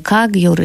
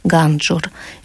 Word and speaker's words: Кагьюр: Это собрание Кагьюр, или, как в России часто произносили в монгольском произношении, Ганджур.